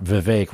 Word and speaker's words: Vivek: And then they'll like make something about Vivek rhymes with cake.